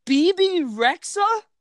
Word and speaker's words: Bebe Rexha: Is it Bebe Rexha? Is that her name? – Yeah.